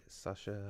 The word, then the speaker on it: Sacha: Sacha.